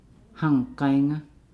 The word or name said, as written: hang-gainga